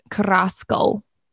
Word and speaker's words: Carrasco: Carrasco, I think is how you say it. You roll the R's.